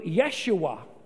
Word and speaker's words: Yeshua: Jesus is the Greek form of the Hebrew Joshua, or pronounced in Hebrew, Yeshua,